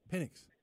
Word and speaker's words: Penix: Like, I – Penix.